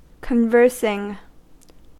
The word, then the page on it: conversing: American English